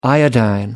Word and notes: iodine: American English

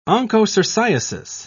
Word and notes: onchocerciasis: British English

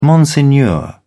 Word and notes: monsignor: British English

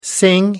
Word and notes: sing: American English

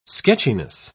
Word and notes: sketchiness: British English

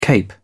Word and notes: cape: British English

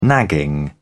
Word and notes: nagging: British English

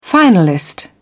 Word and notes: finalist: British English

